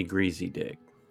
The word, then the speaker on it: Griesedieck: It's definitely Greasy Dick. – Yep. – It really is. – That is how you pronounce it. – Yeah. I just happen to know like a father that unfortunately he's Father Greasy Dick. – Yep. G-R-I-E-S-E-D-I-E-C-K is how you spell it. But yeah, that is how you pronounce the name.